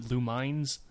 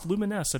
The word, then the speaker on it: Lumines: Lumines Remastered, and I've confirmed that's how you're supposed to pronounce that. Ever since it came out in like what 2006, I called it Lumines, but it's Lumines apparently.